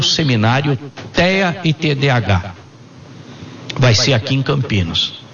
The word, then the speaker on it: TDAH: Nós estamos conversando aqui sobre TDAH, nós estamos falando também, vamos falar... Tô falando certo, TDAH. – É isso. Isso. – Junto, eu falo devagarzinho que a pessoa entender as letras, né?